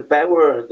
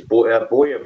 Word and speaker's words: Bower: I didn't know this, but actually the Bauer or the German pronunciation of it is Bauer, B-A-U-R or something like that, isn't it, for my name? But my name Bauer actually is a Babylonian name.